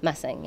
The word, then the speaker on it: Mussing: And I don't use Anna Mussing because when British people pronounce it, they pronounce it amazing. So then my name is Amazing, which is kind of awesome, but sounds fake.